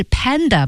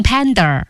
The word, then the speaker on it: panda: 哎，我发现有些同学啊，我们后来几位同学都有这个问题，就是 panda 是 panda 吧？不要读成 panda。